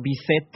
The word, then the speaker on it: Bizet: Entonces, el compositor de esta ópera, que se llamaba Georges Bizet, Bizet, se escribe B-I-Z-E-T, lo exportó, bueno, lo importó a España de La Habana, de Cuba.